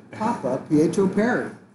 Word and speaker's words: Papapietro Perry: Papa Pietro Perry.